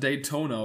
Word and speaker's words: Daytona: In der amerikanischen Stadt Daytona oder Daytona, ich weiß nicht genau, wie es ausgesprochen Daytona.